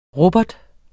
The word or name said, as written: rubber